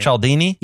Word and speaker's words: Cialdini: Cialdini? – Yes.